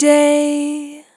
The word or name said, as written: Long Day